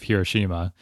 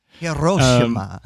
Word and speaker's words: Hiroshima: I also hear horrible pronunciations of Hiroshima. It is not Hiroshima. It's not, just because it's another language doesn't mean it's Spanish. So Hiroshima.